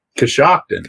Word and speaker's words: Coshocton: How do you say the town you're in? Coshocton? – Coshocton. – Coshocton. Okay.